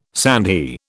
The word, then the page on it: sandhi: British English